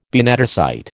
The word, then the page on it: benedicite: American English